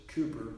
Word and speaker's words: Cowper: But in the words of William Cowper's, Cooper, technically it's spelled C-O-W-P-E-R, but pronounced Cooper, this fountain is filled with blood drawn from Emmanuel's veins, and sinners plunged beneath that flood lose all their guilty stains.